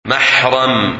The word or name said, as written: Mahram